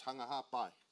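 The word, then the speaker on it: Hunga Ha'apai: And a fun fact for the Tongans, the P is actually pronounced with a B. So it's Hunga-Hapai.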